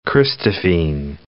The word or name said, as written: christophene